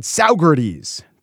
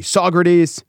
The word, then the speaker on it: Saugerties: To wit, on the show the other day, I said Saugerties, town in upstate New York. It's actually Saugerties, bad pronunciation.